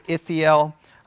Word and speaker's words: Ithiel: Transliteration, Ithiel, pronunciation, Ithiel. A part of speech, proper masculine noun.